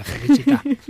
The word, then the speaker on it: Felicità: Sí, la canción se llama Felicidad, en italiano felicita no sé cómo se pronuncia el italiano. bien pronunciada, Felicità.